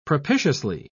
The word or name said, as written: propitiously